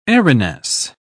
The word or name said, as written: airiness